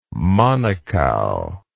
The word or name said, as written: manukau